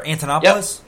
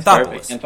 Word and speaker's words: Anthopoulos: It's Alex Anthopoulos, right? Or Antonopoulos? – Yep. Anthopoulos. Anthopoulos, yeah.